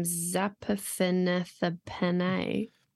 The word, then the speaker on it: Zaphnath-Paaneah: And then Pharaoh calls Joseph's name Zaphnath-Paaneah.